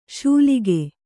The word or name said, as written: ಶೂಲಿಗೆ